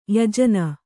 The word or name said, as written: ಯಾಜನ